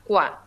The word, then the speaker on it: Kua: K-U-A, Qua, así, ¿no?